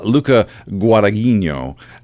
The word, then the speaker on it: Luca Guadagnino: but Luca Guadagnino.